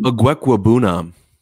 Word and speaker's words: Okwuegbunam: Um, Okwuegbunam.